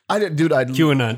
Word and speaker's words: QAnon: I think it's Quanon. – I don't know. Two letters of capital. – QAnon. Don't worry.